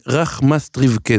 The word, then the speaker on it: רחמסטריווקא: אה, הכי קשה להגייה אצל אנשים שלא יודעים. נכון, אבל זה רח מסטריפקה, זו עיירה...